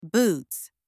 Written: boots

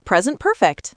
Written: present perfect

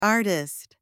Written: artist

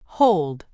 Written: hold

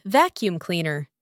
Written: vacuum cleaner

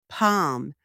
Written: palm

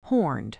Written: horned

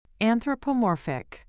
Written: anthropomorphic

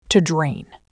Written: to drain

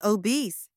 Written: obese

